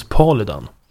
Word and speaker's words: Paludan: Jag sa ju tidigare Rasmus Paludan. Fick jag höra sen att jag var riktigt dum i huvudet som sa så. För han heter ju Paludan. Rasmus Paludan. Men Kasselstrand ger en ledtråd, för han uttalar namnet på ett annat sätt. Han säger Rasmus Paludan. – Det låter ju danskare faktiskt. – Exakt, det är lite dansk, det är lite dansk touch. Så istället för att det är liksom A-U-A vokalerna, så är istället Ä-Ä-Ä som nästan alla vokaler på danska språket.